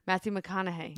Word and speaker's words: Matthew McConaughey: Matthew McConaughey.